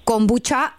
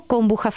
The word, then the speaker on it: kombucha: Chceš kombuchu, máte taky na Slovensku kombuchu a vyslovuješ to kombuča, anebo kombucha s ch.